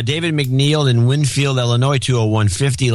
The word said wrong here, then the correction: McNell: Last name rhymes with Bell. McNell. David McNell. It looks like an I, sorry.